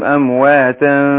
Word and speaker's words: أمواتا: أمواتا ف، عندنا أمواتا نون التنوين اللي هنا أهي، أنا بنطق في الآخر بعد التنوين بنطق نون ساكنة، أمواتا هي ما بتتكتبش لكن إيه تنطق، فأقول أمواتا نون التنوين دي جه بعدها الفاء من حروف الإخفاء، فبخفي نون التنوين عند الفاء وأقول أمواتا فاء أمواتا فاء كده.